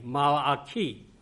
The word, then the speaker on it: Malachi: 这个马拉基这个名字这个意思啊，有时说我的天使 Malachi 啊，这是这个希伯来文的发音 Malachi，我的天使。